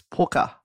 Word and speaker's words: Pwcca: Some of it would say it's a jug of milk for the – now, it's spelt P-W-C-C-A, but apparently it's pukka.